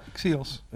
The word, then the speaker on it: XIOS: Directeur bij het XIOS, zeg ik dat goed? Ja, XIOS. Telemedisch Centrum.